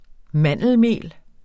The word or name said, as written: mandelmel